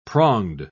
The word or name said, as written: pronged